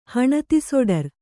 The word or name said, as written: ಹಣತಿಸೊಡರ್